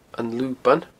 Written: An Luban

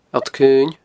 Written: Allt Caoin

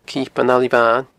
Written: Cipean a Libhain

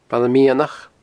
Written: Baile Meadhanach

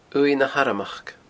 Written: uaimh na harmachd

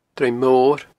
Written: Druim Mor

